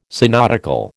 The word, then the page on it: synodical: American English